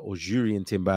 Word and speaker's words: Jurrien Timber: And listen, with Jurrien Timber, as it's meant to be pronounced, my apologies, in the mix, I think we just about had enough to cover those things and cover those possibilities.